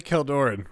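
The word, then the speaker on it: Kjeldoran: Yes, now that we have the official pronunciation. – Yeah, it's definitely Keldoran, right? – Yeah, Keldoran Outpost. Yeah.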